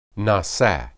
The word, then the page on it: nisa: hebrew